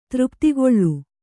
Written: ತೃಪ್ತಿಗೊಳ್ಳು

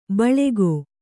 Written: ಬಳಗ

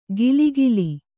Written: ಕಿಲಿಕಿಲಿ

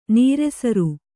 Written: ನೀರೆಸರು

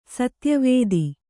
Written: ಸತ್ಯವತಿ

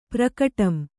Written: ಪ್ರಕಟಂ

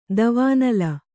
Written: ದೇವನಾಲ

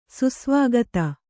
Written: ಸುಸ್ವಾಗತ

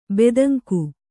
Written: ಬೆದಂಕು

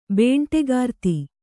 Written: ಬೇಂಟೆಗಾರ್ತಿ

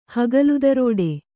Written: ಹಗಲುದರೋಡೆ

